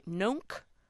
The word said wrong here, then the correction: Noank: This is William in Noank.